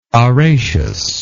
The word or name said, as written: araceous